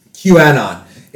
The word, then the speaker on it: QAnon: The Snyder Cut is just Quanon for nerds. – QAnon. It's just QAnon for nerds.